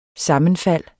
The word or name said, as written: sammenfald